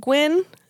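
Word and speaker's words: Gwynne: Fred Gwynn, is that how you say it? – Yes, yes.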